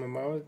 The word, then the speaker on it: Momoa: The Jason Momoa casting of Jason Momoa. That's his name. – Yeah. I think you said it right.